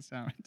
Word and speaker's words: salmon: Oh, it's not salmon. Like, exactly. Que no se dice salmon? What do you mean salmon? Exactly. Like, there's an L there, fam.